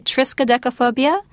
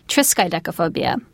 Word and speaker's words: triskaidekaphobia: And it's a very long word, triskaidekaphobia. – How do you spell it? – Well, you spell it like this, TRISKAIDEKAPHOBIA, and it can be pronounced one of two ways. It's either triskaidekaphobia or triskaidekaphobia. So one or the other. Now, it's a word that we don't use very often, but it just goes to show that we have a special word for this condition, that people are so afraid of this unlucky number. Phobia in triskaidekaphobia means the fear of, and triskaideka refers to the number 13.